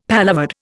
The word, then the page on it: paloverde: Indian